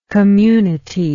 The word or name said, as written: communital